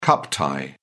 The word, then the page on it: cup tie: British English